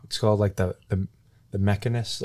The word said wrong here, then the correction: Machinist: or the Machinist.